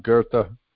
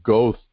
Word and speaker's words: Goethe: It looks like Goethe to me, but it's Goethe. G-O-E-T-H-E. There's no R in there, and yet it's pronounced as if there is an R in there.